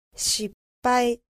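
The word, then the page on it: 失敗: Japanese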